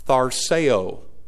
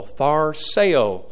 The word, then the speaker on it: tharseo: Tharseo. Three syllables, the accents on the middle syllable say.